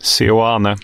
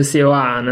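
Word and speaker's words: Seoane: Sioane. – Bra. Gerardo Sioane.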